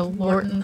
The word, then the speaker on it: Lorton: Following this, the asylum was shut down and all patients were transferred to Lorton Prison. I hope I'm not mispronouncing that. – No, I think that's right.